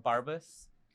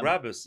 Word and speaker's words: Brabus: Is it Barbus? – Brabus.